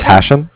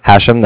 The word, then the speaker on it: Hashem: My name's Hashem. Hashem, that's H-A-S-H-E-M.